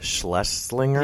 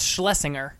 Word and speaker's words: Schlesinger: It's actually, you actually said it wrong. It's Schlesinger. So you did say it wrong.